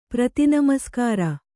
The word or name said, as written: ಪ್ರತಿನಮಸ್ಕಾರ